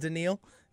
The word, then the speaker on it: Daniil: It's spelt like Danielle, but it's pronounced Daniil.